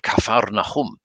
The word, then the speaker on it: Capernaum: And we went to Capernaum, which of course he called Kafarnachum, which sound, sounded amazing. And I've always wanted to say that when reading it, which of course it would, would be so distracting for people, they wouldn't hear the rest of the reading. So it was very exciting to hear it properly pronounced.